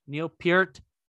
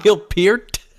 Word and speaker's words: Peart: That's how you say his name, dude. – Is it really? I thought it was Neil Peart. – I know. They've corrected the entire world, especially when he died. That's Neil Peart.